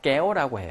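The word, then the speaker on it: kéo: 자, 베트남어로 깨오라고 해요. 깨오. 자, 이 정도 이제 몰랐다고 쳐. 이제 선생님이 발음을 한번 해줬어. 깨오라고. 자, 그러면 깨오라고 하는 끄 발음이 나는 것은 베트남어에서 C하고 뭐가 있어? K가 있어. 자, K가 있지? 그래서 이게 깨오야.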